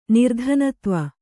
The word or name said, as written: ನಿರ್ಧನತ್ವ